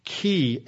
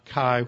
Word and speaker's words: chi: which is the Greek letter key as we pronounce it uh, today, not chi, which is how you learned how to pronounce it if you were in some sort of uh, fraternity.